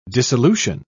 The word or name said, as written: dissolution